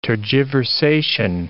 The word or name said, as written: tergiversation